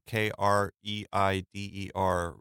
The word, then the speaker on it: Kreider: I can't remember if it's Kreider or Kreider. K R E I D E R wrote an essay for this movie in Film Comment. I think shortly after it came out. – You got his name right.